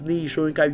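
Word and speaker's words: Niyi Soyinka: My name remains Nii Ishoyinka.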